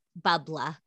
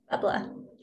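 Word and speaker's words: bubbler: The other part that I laughed at in the book was Bubla. – Bubla. Yeah. – Yeah. Cause I'm like, you know, if you live in Massachusetts, like that's how you say it.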